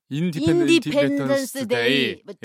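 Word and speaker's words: Independence Day: 제가 그곳에서 처음으로 영화관을 봤었는데 그 영화가 바로 외계인이 나오는 인더펜더스데이. – 인디펜던스 데이. – 예. 독립 기념일. – 네. 아, 방금 정정해 주셔서 너무 네.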